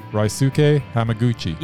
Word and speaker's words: Ryosuke Hamaguchi: Haruushka Yamaguchi. – Ryosuke. – Yeah. – Hamaguchi. – Yeah, Ryaguchi. – No, Ryosuke. – Say it again. – Ryosuke Hamaguchi.